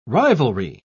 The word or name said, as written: rivalry